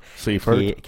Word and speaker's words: Seifert: Je m'excuse pour la prononciation S-E-I-F-E-R-T, donc Seifert. Seifert. Seifert, merci. merci pour le prononcer.